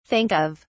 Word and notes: think of: British English

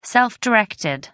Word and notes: self-directed: American English

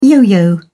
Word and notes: yo-yo: English; British English

